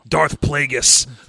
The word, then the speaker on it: Darth Plagueis: Darth Plagueis.